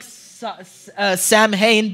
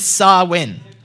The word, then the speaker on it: Samhain: So, now I know this looks like Samhain, but the way it's actually pronounced is Samhain.